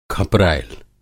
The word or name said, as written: खपरैल